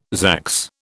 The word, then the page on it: zax: British English